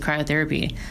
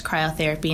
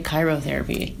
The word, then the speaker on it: cryotherapy: I've heard people say chirotherapy. – Yes. That's totally. So, wrong.